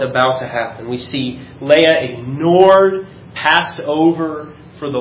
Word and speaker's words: Leah: By the way, I know it's pronounced Leah, but I've said Leah for so long that I can't get it out of my head.